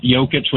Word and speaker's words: Jokic: And, you know, sometimes they pronounce Jokic with the hard J still, but at least they, like, know who his name, like, who he is, right?